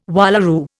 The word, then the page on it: wallaroo: Indian